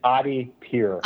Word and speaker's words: Adipure: It's Adipure.